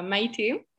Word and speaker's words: Maite: So actually, it's pronounced uh, Maiti.